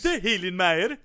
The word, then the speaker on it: Hunter Hillenmeyer: Hunter Hillenmeyer. – That's how you pronounce it.